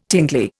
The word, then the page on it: tingly: Indian